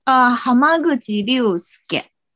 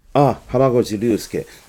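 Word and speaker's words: 濱口竜介: Hamaguchi Ryusuke。 – Hamaguchi Ryusuke。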